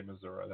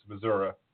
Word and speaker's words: Missouri: So you have to say Missouri. That's how they say it in the Missouri. It's Missouri.